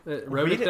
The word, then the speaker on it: rhotic: I may not be pronouncing that first one right. – That sounds interesting. Rhotic, I think. – Read it like Scooby-Doo. – Rhotic replacement. – There we go.